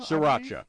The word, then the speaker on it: Sriracha: Sriracha. Yeah, that's closer. – No problem. – And they had a dry powder seasoning, sriracha seasoning, that they mixed in with the breading.